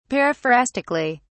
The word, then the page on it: periphrastically: British English